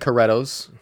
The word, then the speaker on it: Caretos: E-T-O-S. – C-A-R-E-T-O-S. Caretos? – Yeah, it looks like Caretos. – It does. – If I told you it was Portuguese, would that change the way you said that word? It's pronounced Portuguese? – No, it is Portuguese. – No. – Caretos. – See, that's how I would have said it, right? – Yeah.